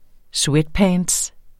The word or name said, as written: sweatpants